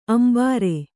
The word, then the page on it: ಅಂಬಾರೆ: ambāre